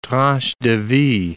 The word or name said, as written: tranche de vie